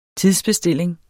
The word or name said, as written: tidsbestilling